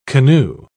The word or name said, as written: canoe